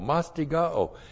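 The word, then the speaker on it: mastigao (μαστιγόω): It's mastigao. M-A-S-T-I-G-O, long O, mastigao.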